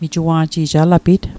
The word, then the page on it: alapit: Mi'gmaq